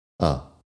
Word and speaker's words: a: Ya he mencionado un poco, pero normalmente el artículo a se pronuncia a. La letra e se pronuncia A. E, a, B, C, D, E, F, G. Pero el artículo es Ah.